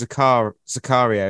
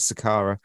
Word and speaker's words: Zakaria: I also realised I, for some reason, pronounced Zakaria as Sakara.